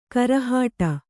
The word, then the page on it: ಗುರಿಹೊಡೆ: guri hoḍe